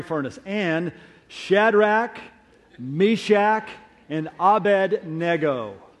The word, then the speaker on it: Abednego: I know what you're thinking. He mispronounced that. No, I didn't. You probably learned it as Abednego, right? Abednego. When I grew up, I think my parents inverted the D and the N, and I thought it was Abednego, until I read the story and went, they misled me all these years.